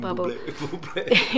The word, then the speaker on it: Bublé: And so I can't hear his name without thinking of Michael Bublé.